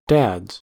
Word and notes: dads: American English

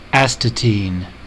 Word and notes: astatine: American English

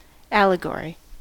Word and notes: allegory: American English

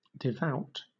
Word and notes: devout: British English

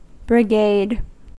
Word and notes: brigade: American English

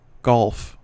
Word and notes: golf: American English

